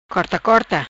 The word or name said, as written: Corta Corta